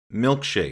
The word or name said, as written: milkshake